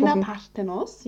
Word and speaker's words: Athena Parthenos: Ha Habe ich da richtig ja, ausgesprochen? Hast du richtig Athena Parthenos, ja.